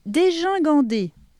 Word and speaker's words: dégingandé: Puis, dégingandé, puisque gin, G-I-N, ça fait gin, hein, comme dans gingembre.